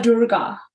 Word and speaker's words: Durga: So there is one goddess, she's called Goddess Durga. Mm-hmm. So if you heard about Kali, it's very, very like fiery, like don't mess around with me. Otherwise, say her name again. What's her name? – Durga.